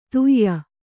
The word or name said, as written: ಧೇಯ